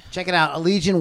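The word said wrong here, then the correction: Allegaeon: That's the band Allegion. You have to say it like that. Allegion.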